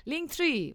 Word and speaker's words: Linktree: o como se pronuncia en inglés bien, Linktree,